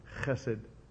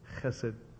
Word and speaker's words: chesed: That word for loving kindness there in the Hebrew is chesed, chesed. When I teach my students in Greek or either in Hebrew, either one, to pronounce those letters that go, it's a hard thing. We're not used to doing that in English.